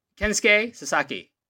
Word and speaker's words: Kensuke Sasaki: Kensuke Sasaki.